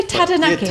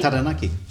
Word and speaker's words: Taranaki: No, just say Taranaki. – Yeah, Taranaki. – See? – Yeah, it sounds like it. Sounds great, doesn't it? Yeah. And it's how it should be pronounced.